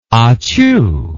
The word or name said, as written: ahchoo